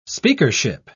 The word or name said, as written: speakership